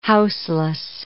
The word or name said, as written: houseless